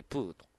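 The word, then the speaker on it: 黄熊: 黄色という漢字に熊と書いて、プーと。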